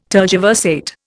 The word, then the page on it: tergiversate: Indian